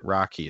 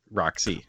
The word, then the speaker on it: Roxy: We also have to remember that Treats grew up at a different time than we did, and thus he has the accent and pronounced it Rocky instead of Roxy. – What are you guys talking about? One, I've always pronounced it Roxy.